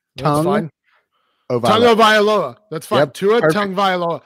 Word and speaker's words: Tagovailoa: I will teach the whole world how to say this last name correctly because it's not that hard. Don't ask me to say his first name though. – Tagovailoa.